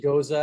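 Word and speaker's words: Gose: Do you pronounce it Goza? What's the proper pronunciation? – I pronounce it Goza.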